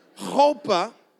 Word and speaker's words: chuppah: Chuppah. It's spelled like chalupa. Actually, it's not spelled like chalupa at all. There's no L in it. But it's kind of spelled that way. But it's pronounced chuppah, and a chuppah was his first house.